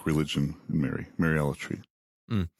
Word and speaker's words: Mariolatry: And so I come from that, you know, but even hearing the word Mariolatry, right, which, you know, it rhymes with idolatry, you know, is sort of what's being gotten at with that.